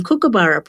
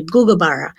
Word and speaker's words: kookaburra: Because people may not realise that they're not pronouncing the name Kookaburra properly. We don't have that K or C sound in our languages. It's closer to a G. So Kookaburra is actually Gugabara.